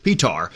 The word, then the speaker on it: Petar: Am I saying that right? Is it Peter or Petar? – Petar.